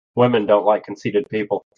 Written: conceited

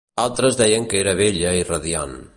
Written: bella

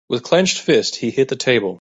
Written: clenched

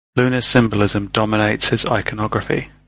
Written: dominates

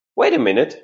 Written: wait a minute

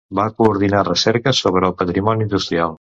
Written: coordinar

